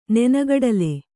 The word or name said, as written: ನೆನೆಗಡಲೆ